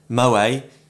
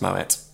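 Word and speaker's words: Moët: Moe, common misconception, it's pronounced Moet.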